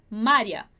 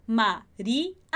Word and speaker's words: María: Aqui a gente separa o I do A, como eu já expliquei algumas vezes antes, porque a gente tem acento nessa letra I. Se a gente não tivesse acento em Maria, a gente pronunciaria Maria. E não é isso que a gente quer dizer. Então, Maria.